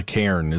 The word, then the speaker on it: cairn: And never disturb the cairn. Is that how you say it? The standing stones? – Mm-hmm. Yeah.